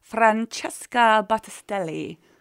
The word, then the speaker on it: Francesca Battistelli: And then also Friday at the Arena, some of the biggest names in Christian music coming including Skillet, Jeremy Camp, Francesca Battistelli.